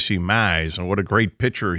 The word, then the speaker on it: Mize: we caught some play by play of him doing a Tigers spring training game, and Buck Martinez turned the last name Mize into a multi syllable name. – Mize.